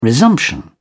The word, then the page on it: resumption: British English